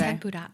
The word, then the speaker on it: tempura: So I have to ask, because you said this earlier, and I just have to ask, is the correct pronunciation tempura, not tempura? – Yes. – Like we say. – Tempura.